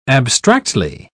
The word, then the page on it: abstractly: British English